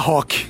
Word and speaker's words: rock: In portoghese samba rock. – Rock. La R non si pronuncia. – Non la R all'inizio, no.